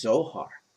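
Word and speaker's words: Zohar (זֹהַר): And the phrase as the brightness is Strong's H2096, pronounced Zohar.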